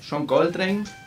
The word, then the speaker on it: Coltrane: Eh, número 3, John Coltrane. – También. – Eh, lo dije bien. – Muy bien, muy bien, lo pensaste.